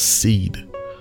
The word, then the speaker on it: Cede: It's called Seed. That's spelled C-E-D-E. Seed.